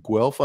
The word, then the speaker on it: Guelph: So, born and raised in Guelph, Ontario, is that right? – That is correct.